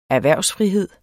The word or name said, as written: erhvervsfrihed